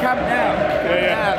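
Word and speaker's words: Camp Nou: So we went to the new camp on Tuesday. – That's the now camp. – The camp now. There we go. The camp now, as the locals say.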